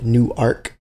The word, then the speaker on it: Newark: Oh yeah, it's so anyone who's from there, you pronounce it as Newark, like N-O-R-K. But when you read it, it's spelled Newark, like new, like Noah's Ark.